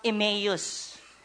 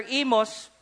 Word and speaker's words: Emmaus: They say it's pronounced Emmaus. But you know, when you've been to the place, you know how it's said.